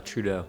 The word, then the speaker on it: Trudeau: Trudeau.